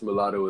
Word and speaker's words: Mulatto: So did you listen to this girl named Miss Mulatto? Is I'm saying that right? – Yes, Miss Mulatto is hard.